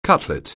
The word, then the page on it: cutlet: anglais